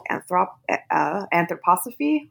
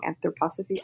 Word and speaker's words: Anthroposophy: He founded a philosophy called Anthroposophy. I'm saying that wrong. Anthroposophy.